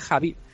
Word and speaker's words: Khabib: que por cierto no es Khabib, y realmente lo estamos pronunciando mal, no es Khabib, es Javi.